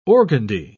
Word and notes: organdie: British English

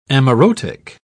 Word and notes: amaurotic: British English

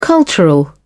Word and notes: cultural: British English

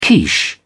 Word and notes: quiche: British English

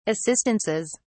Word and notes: assistances: British English

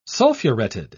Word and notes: sulfureted: British English

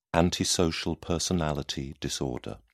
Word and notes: antisocial personality disorder: British English

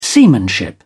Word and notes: seamanship: British English

